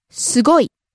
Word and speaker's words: すごい: すごい。 – Next. – 安い。 – Cheap. – 安い、安い。 – Rising on the second syllable, す, coming down on the last syllable, い。